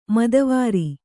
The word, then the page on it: ಮದವಾರಿ: madavāri